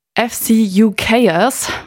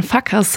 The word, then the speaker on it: FCUKERS: Jetzt muss ich gucken, dass ich es richtig ausspreche. FCUKers, weil ich würde gern Fuckers sagen, haben das geschafft.